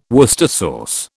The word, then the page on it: Worcester sauce: British English